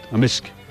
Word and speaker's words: amisk: Beaver? Amisk. – Amisk. So the K can be silent, but in general it's...